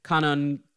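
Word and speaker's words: Kwannon: So just thank you again for writing in with your Kanon questions with a silent W.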